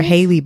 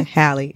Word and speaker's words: Halle: I don't know how you pronounce Halle or Haley. She's the second part of the singing group, Chloe and Halle. – So it's Halle. – Yeah, Halle. It is.